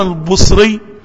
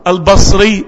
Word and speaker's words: البصري: فثلاث لغات في البصري الحسن البصري بالضمة الحسن البصري بالفتحة الحسن البصري بالكسرة ويصلح الثلاثة.